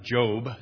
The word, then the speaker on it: Job: He pronounced it Job, so that's what I'm going to do.